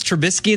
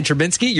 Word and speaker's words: Trubisky: If you mispronounce Trubisky and say Trubinsky, you're getting right on.